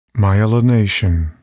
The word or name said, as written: myelination